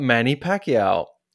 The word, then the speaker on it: Pacquiao: And I was reading through the news and I came across a story about Manny Pacquiao.